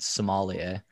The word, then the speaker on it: sommelier: When I look at your YouTube channel, knowing now that you're a certified sommelier, that I said it right. – You got it, man.